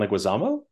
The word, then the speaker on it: Leguizamo: Leguizamo? – John Leguizamo, the actor? – Oh, I said, yeah, he's in it.